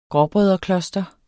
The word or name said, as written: gråbrødrekloster